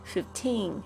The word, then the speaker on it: fifteen: 这个声音啊，因为你讲成 fifteen fifty 可能会跟那个五十这个 fifty fifty 这个搞错，所以要小心一下这个 fifteen 的这个正确的发音哈，f i f t e e n 这样子的拼法才是正确的，而且还有它的说法就特别注意一下 fifteen fifteen。